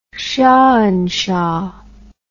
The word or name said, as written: shahanshah